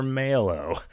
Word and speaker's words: Mallow: Also, do you pronounce it Mallow or Mallow? I say Mallow as in marshmallow. – How many L's are in it? – It's M-A-L-L-O-W.